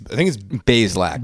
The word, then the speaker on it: Bazelak: I think it's Bazelak. – Bazelak. Yeah, it's just two syllables.